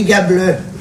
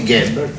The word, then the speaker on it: Gable: Il y a écrit Gable. On doit prononcer Gable. Ah. Moi, je vois écrit gars, je dis gars.